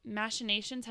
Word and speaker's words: machinations: Because through machina- machinations, how do you say it? Machinations. Yeah. Of